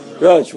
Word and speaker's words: rosh: The Hebrew word for head is rosh, which is spelled resh aleph shin. But there is no aleph when we say it.